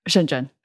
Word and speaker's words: Shenzhen: In the south of China, right across the border from Hong Kong, called Shenzhen. – Oh, okay. You say that much better than I imagine I would. – Shenzhen. Now you know how to say it.